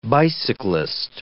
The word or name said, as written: bicyclist